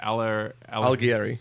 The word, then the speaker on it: Alighieri: Alighieri.